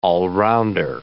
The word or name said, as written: all-rounder